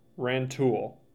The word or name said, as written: Rantoul